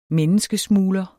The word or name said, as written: menneskesmugler